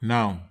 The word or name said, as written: nau